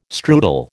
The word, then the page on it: strudel: American English